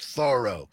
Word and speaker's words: Thoreau: I love this quote from Thoreau, or I visited his place and they called it, it's Thoreau. It's pronounced Thoreau.